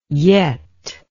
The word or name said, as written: yett